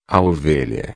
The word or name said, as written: ovelha